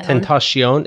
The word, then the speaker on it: Tentacion: I wouldn't even say about, but about XXX Tentation. – Tentacion. – Is that really how you say it? – Mm-hmm.